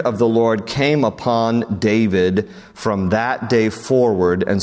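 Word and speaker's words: David (דוד): David, his name in Hebrew, David, is how you pronounce it, David.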